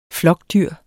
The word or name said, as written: flokdyr